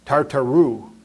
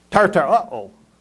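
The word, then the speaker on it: Tartaroo: And I used to pronounce it Tartaru, because that's what you would think of it as, Tartaru. No, it's Tartaro.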